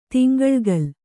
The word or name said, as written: ತಿಂಗಳ್ಗಲ್